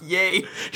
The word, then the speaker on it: Ye: Jesus Christ, it's pronounced yay. – They said ye in this. I made a very No, they say it's yay as in Kanye and then Yeezus as in Jesus.